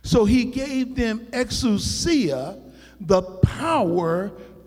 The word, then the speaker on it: exousia: Now the Greek word here is the word exousia. Exousia. Now that word is given to us, and that's spelled E-X-O-U-S-I-A. E-X-O-U-S-I-A.